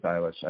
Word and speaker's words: Eilish: I think it's Eilish. I always hear it pronounced different ways.